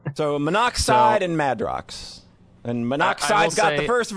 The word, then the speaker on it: Madrox: One guy's named Monoxide and the other guy's name is Madrix. Is that how you fucking pronounce that shit? – I think it's Madrox.